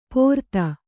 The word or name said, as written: ಪೋರ್ತ